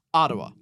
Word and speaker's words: Adua: It is spelt Ardua, A-D-U-A. Ah. Not Ardua. Yeah, so Ardua. – Ardua. – Yep. – Ottawa. – Sounds like Ottawa.